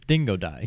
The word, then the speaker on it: Dingodile: It's Dingodile. He's like an Australian crocodile.